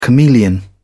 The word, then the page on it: chameleon: British English